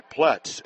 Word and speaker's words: Ploetz: Connor Pets, or I should say Pletz, a freshman steps in, spelled P-L-O-E-T-Z. You don't pronounce the O.